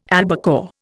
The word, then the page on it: albacore: Indian